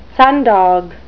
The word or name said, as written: sundog